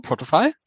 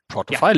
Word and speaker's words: Protofy: habe ich es richtig ausgesprochen? Protofy? Protofy, ja.